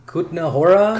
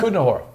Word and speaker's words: Kutná Hora: Kutnahora? – Kutnahora.